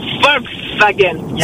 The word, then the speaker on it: Volkswagen: Alors, on dit Volkswagen ou Volkswagen? – Volkswagen. Yeah. – C'est Volkswagen. – Wagen. – W. Wagen. – Ah, on prononce V.